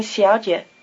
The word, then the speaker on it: 小姐: 她是马小姐 Try repeating 小姐, the word for miss.